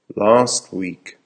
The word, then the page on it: last week: American English